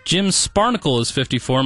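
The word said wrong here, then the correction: Spanarkel: Am I saying that right? – Spanarkle. Former Maverick great. Sparnacle.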